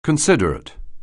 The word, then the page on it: considerate: anglais